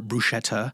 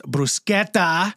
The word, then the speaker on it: bruschetta: So no more bruschetta. It's bruschetta.